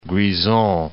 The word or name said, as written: Grisons